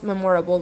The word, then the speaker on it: memorable: My most memorable, accent on the first syllable.